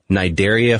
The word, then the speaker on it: Cnidaria: Like Robert said, they're all members of the Cnidaria phylum, and Cnidaria has a C in front of it. The C is silent.